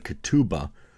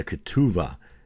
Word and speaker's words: ketubah: We now switch to the topic of marriage and the marriage contract specifically, which is called the ketubah, or the ketuvah, as he pronounces it.